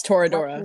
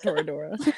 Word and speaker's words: Toradora: It's Toradora. – Listen and say it wrong. Okay. – Well. It's just straight up Toradora. – Toradora. – You don't have to roll your R's.